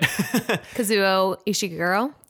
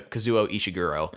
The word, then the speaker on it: Kazuo Ishiguro: Kazuo Ishiguro. – There you go. Kazuo Ishiguro.